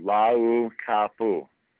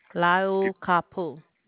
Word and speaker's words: Laukapu: Okay, so it's 5-6-7-A. How do you pronounce the L word? – Lau. Just the way it's spelled. Laukapu. – Laukapu. – You've heard of ukulele, yeah? – Yes. – U-K-U, uku. L-E-L-E, lele.